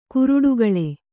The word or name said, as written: ಕ್ರೀಡಾಕುಳಿ